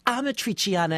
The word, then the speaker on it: Amatriciana: It was called Matriciana. I'm not even sure I'm saying it right. – It's Amatriciana, and you're saying it right. You just needed an A. – Can you tell me how to spell it? – I'm sounding it out. A-M-A, Amatri, T-R-I-C-I-A-N-A.